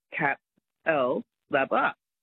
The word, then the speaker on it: Capalaba: If you look at it, it's C-A-P-A-L-B-A, Cap-L-Labba.